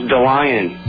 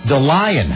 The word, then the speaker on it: DeLion: His name's DeLion. – DeLion. How do you spell that? – It's Dandelion, no Dan. – Wait. So just D-E-L-I-O-N? – Yep.